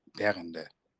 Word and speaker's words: Berende: My guest today is Bastiaan Berende.